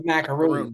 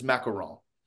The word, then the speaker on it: macaron: I thought it was macaroon. Not that I could really. – No, if you pronounce it in French, it's macaron.